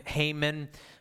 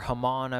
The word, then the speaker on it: Haman: Well, in the midst of this, the other plot going on is this guy, a lot of people call him Haman in our English, or Haman, I've heard him pronounced as well.